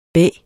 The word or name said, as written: bæ